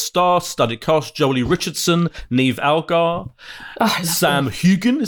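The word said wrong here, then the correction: Heughan: is that how you pronounce it? From out of. – Sam uh, Hewen. Hewen.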